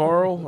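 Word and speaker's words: Arle: Fields of Arl or Fields of Arla. I don't know how you say it. So Arl. – Fields of Arla. – Yeah, you say it way better than I could.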